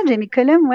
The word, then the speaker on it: Jamie Cullum: Ouais, je pense que c'est pas mal, Jamie Cullum, ouais.